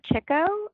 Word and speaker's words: Chico: I am at Jennifer Chico on Twitter.